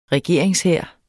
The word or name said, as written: regeringshær